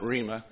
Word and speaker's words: rhema: And yes, it is actually pronounced rhema and not rhema.